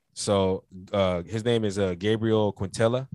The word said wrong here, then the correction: Quintanilla: I think I'm saying it wrong. Or yeah. Quintanilla. There we go.